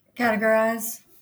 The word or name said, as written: categorize